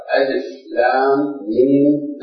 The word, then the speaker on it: الم: ما اقول الف، اقول الف لام ميم. نعم في بعض السور هذه ليست حروف، هاي كلمات فتتلفظ كلمة، مثل سورة الانشراح. سورة الانشراح اولها شنو؟ بسم الله الرحمن الرحيم، ألم نشرح لك صدرك، هذه ألف لام ميم كلمة ليست حروف، ليست ألف لام ميم، هذه كلمتان، ألم كلمتان، الهمزة أداة استفهام، ولم أداة جزم، فهما كلمتان الهمزة ولم، فنقراها شنو؟